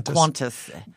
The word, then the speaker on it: Qantas: Qantas? – The Qantas.